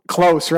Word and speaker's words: Close: I mean that that has growth written all over it, and I think you're I think you're so right, Coach Coach Close, close, right?